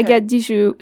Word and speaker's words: Agathe Dijoud: Agathe Dijoud. So you don't pronounce the D at the end, but even French people will get it wrong. So you got it right.